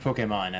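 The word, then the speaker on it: Pokémon: Yeah, I tell you what, there were a lot of Pokemon at the convention.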